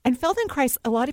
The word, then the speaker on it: Feldenkrais: What we say is Feldenkrais rhymes with rice.